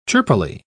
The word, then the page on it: chirpily: British English